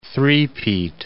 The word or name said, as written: three-peat